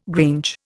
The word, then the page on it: grange: Indian